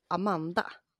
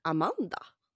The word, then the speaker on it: Amanda: Men jag tänker så här, betoningen på Amanda. För här, nu, det här är roligt. För i Stockholm så säger vi Amanda. – Ja. – Men betoningen här uppe är Amanda.